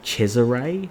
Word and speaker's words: Cesare: But there's this one, I can't pronounce her name, it's Donatella di, I want to say Cesare, it's spelt like Caesar, C-E-S-A-R-E, and it's her book Immunodemocracy.